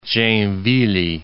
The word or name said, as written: Joinville